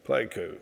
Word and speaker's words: Pleiku: Talk a little bit about, because I have a brilliant note here, and I'm going to butcher the name, Pleiku trip? – Pleiku.